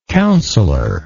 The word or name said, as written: councilorship